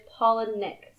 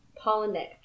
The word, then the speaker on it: Palahniuk: And according to his Twitter, it is Palahniuk. So I'm always saying it right. Palahniuk.